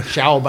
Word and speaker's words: Schaub: That's probably a good German pronunciation right Schaub? – There. Schaub. I think that's probably the true German pronunciation.